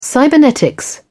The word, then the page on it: cybernetics: British English